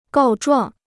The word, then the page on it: 告状: gào zhuàng